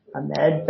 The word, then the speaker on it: Ahmed: And the actor who did Jar Jar's voice, I'll own it, I didn't bother to check the pronunciation of his name, but I believe it's Ahmad or Ahmed, sorry, Ahmed Best.